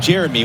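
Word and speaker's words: Yeremy: Because Jeremy Pino, that's how he says his name, Jeremy, with a hard J sound. And yet, any game you watch in the Premier League, any commentator is going to say Jeremy.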